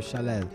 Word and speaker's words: Khaled: Se escribe Khaled, se pronuncia Jev Jaled.